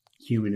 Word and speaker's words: HouMinn: So human is spelled H-O-U-M-I-N-N.